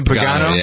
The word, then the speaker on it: Pagano: So, Pagano, is that how you pronounce the name? Pagano? – Yeah.